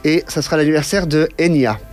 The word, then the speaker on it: Enya: Enya est une approximation phonétique de la prononciation de Eithne en gaélique irlandais.